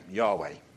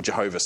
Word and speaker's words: Yahweh: In the Hebrew, it's God's covenantal name, Yahweh, if you want to pronounce it that way, or Jehovah, some might say, Yahweh.